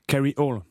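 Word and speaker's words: Kryole: On peut même le prononcer en anglais carry all.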